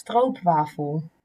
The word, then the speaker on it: stroopwafel: Because you pronounce it as stroopwafel.